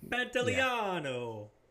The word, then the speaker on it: Pantoliano: Joe Pantoliano. – Mm-hmm. Bro, I can't pronounce that. – Yeah, Joe Pantoliano. – Can I pronounce it because I'm Italian, so we're good. – Pantoliano. – Yeah. Pantoliano.